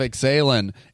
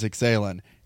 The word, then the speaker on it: Ixalan: Phonetically, if you look at it, it is Ixalan. Ixalan, it sounds like I'm drinking tea.